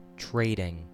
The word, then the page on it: trading: American English